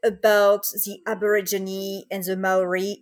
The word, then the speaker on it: Maori: No, you're right. That's how it's pronounced. Maori.